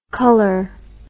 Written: culler

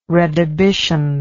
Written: redhibition

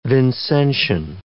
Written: Vincentian

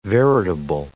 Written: veritable

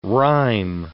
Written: rhyme

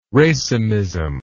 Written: racemism

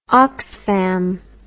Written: Oxfam

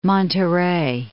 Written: Monterrey

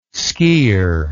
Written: skier

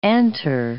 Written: antre

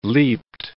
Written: leapt

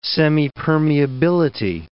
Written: semipermeability

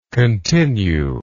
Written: continuingly

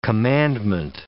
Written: commandment

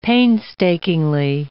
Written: painstakingly

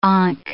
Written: ankh

